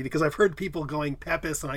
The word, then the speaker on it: Pepys: It's Samuel is the first name, last name, P-E-P-Y-S, and that's pronounced Pepys.